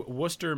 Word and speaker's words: Worcester: The Worcester Baseball Club, moving from Pawtucket, Rhode Island, to Worcester, Massachusetts, which is a city name that is spelled with a whole bunch of unnecessary letters.